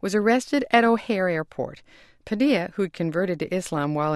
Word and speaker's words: Padilla: In the past, we've pronounced his name Padilla. That's how you've been hearing it on Morning Edition. That would be spelled P-A-D-I-L-L-A. The defendant said it that way in court. – But as he awaits trial on terrorism charges in Miami, his lawyer says his client prefers the Spanish pronunciation Padilla.